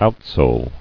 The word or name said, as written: Outsole